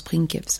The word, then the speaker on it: princeps: Techniquement, ça se prononce princeps. Mais princeps, pardon. Pringles. Que signifie le latin pringles? Non, princeps.